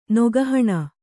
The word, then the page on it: ನೆಗೆಹೆಣ್ಣು: nege heṇṇu